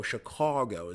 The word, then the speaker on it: Chicago: They find it in Chicago, or they, and that's how they say it over there, by the way. It's a long O. Chicago is what,